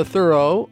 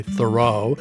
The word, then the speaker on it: Thoreau: Or the Thoreau, and that is how he pronounced it, although most people now, including me, until I was schooled otherwise, say Thoreau.